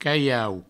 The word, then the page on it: calhau: provençal; caiau